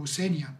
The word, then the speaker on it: Senja: It comes to Scandinavia, I mean, I've been to Norway, I've been to Senja, I think that's the right pronunciation, yep. Senja, yep.